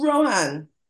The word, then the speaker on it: Rohan: So Rohan, I got the name right? – Yes, ma'am.